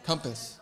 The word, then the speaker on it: compass: Compass. – Compass. It's an O. – Compass. – Compass. – Wow. – Phonetically spelled C-U-M-P-U-S. Compass. – Spelled C-O-M-P-A-S-S.